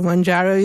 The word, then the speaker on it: Mounjaro: Okay, Mounjaro is the correct pronunciation, making headlines and filling social media feeds, often promoted as miracle solutions for weight loss.